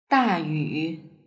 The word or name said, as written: dayu